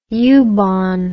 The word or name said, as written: U-Bahn